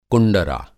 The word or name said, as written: कुंडरा